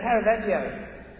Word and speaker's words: Caravaggio: I was looking at that Caravaggio, that's how you say his name, painting, which has got this, you've got Thomas actually sticking his finger in.